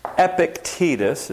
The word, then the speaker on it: Epictetus: Epictetus is how you say that word.